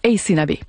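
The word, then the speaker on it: Aysanabee: Alors, je vous appelle, son nom, c'est A-Y-S-A-N-A-B-E. Ça se prononce Ace Nabi.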